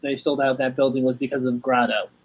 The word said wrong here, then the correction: Grado: Grado, yeah.